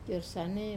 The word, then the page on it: kor sane: wamesa